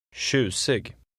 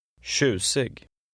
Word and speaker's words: tjusig: Be careful of your pronunciation of the TJ. – Tjusig. Tjusig.